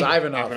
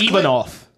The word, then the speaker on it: Ivanov: Versus Blagoy Ivanoy. I don't even know if that's how I say your name, but I wouldn't say it wrong. – I thought it was Ivanov. – So it's Ivanov.